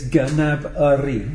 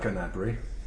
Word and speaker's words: Gnabry: He actually said it in a Twitter thing that he was on, and he actually said it's Gnabry. – He pronounced it. – Well, I'll tell you what, I like Gnabry.